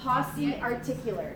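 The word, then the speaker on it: pauciarticular: How do you pronounce it? Pos- Posse articular.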